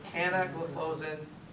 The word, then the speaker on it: canagliflozin: So canagliflozin, epagliflozin, that's technically how these should be pronounced.